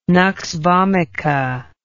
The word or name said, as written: nux vomica